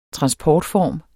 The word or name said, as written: transportform